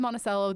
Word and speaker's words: Monticello: So when I lived in Monticello, mm, you know, it, again, it's spelled Monticello like the place in Virginia. It is Monticello.